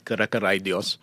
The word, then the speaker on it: Kara Karay Dios: The name, actually, not bad with your pronunciation, but it's called Kara Karay Dios.